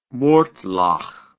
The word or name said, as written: mortlach